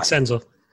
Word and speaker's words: Senzel: Senzel. I don't know how to pronounce that. Yeah, Nick Senzel.